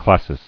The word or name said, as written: Classis